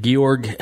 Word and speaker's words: Georg: It's like George without the E on the end. Georg Hegel, late 1700s. – George? – He was, they pronounce it Georg.